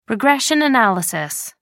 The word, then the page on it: regression analysis: American English